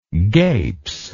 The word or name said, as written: gapes